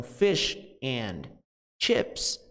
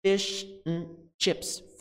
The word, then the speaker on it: fish and chips: Então, fish and chips vira fish and chips, fish and chips, fish and chips. Então, veja como and, palavrinha and, vira n, n, n.